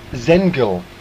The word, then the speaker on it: Senegal: And the other road running close to it is Alderton Road, which is I-L-D-E-R-T-O-N. That's in Southeast 15. Once again, Zengel Fields, S-E-N-E-G-A-L, and that's based on Zampta Road, Z-A-M-P-A.